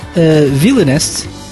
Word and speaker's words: Villainest: So we'll talk to James about his band, Villainest. Am I pronouncing this correctly? – You're pronouncing it perfectly.